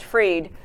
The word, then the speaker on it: Fried: A woman ag commissioner in Florida named Fried or Freed, F-R-I-E-D, is probably pronounced Freed.